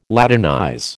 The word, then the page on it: Latinize: American English